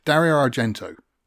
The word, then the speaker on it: Dario Argento: Dario Argento.